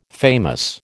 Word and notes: famous: American English

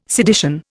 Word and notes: sedition: Indian

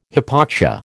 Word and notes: hypoxia: American English

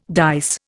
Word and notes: dice: Indian

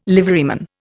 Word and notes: liveryman: Indian

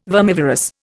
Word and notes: vermivorous: Indian

